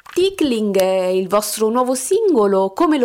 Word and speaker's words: Tickling: È appena uscito il vostro nuovissimo singolo, Tickling, dimmi se dico giusto la pronuncia, ecco. – Giusto, Tickling.